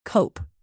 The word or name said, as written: cope